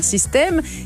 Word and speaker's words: Systaime: Mais pas Système comme un système, Système comme Je T'aime. Enfin, ça s'écrit Système, S-Y-S-T-A-I-M-E.